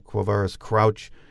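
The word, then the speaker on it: Quavaris Crouch: The one we hear a lot about still is running back Quavarius Crouch. He's out of Charlotte, North Carolina. Eventually, I will pronounce his name right. I'm not sure if that's right, but... – No, I think that's it.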